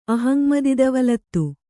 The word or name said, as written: ಅಹಂಮದಿದವಲತ್ತು